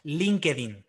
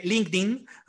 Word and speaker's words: LinkedIn: Eh, es que aquí en España decimos LinkedIn. Sé, que en inglés se dice LinkedIn, ¿vale?